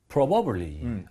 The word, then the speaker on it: probably: 원래 그렇게 발음하면 안 되는데 probably가 맞아요. – 네. – 근데 이게 워낙 빠르게 발음하다 보니까 원어민들 중에 귀찮아서 이 부분을 거의 안 하는 사람들 있을 수 있으니까 이것도 알아두세요. 어떤 사람들은 진짜 probably 그래서 B 헐. – 사운드를 거의 안 내는 사람들도 있습니다.